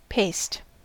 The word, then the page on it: paste: American English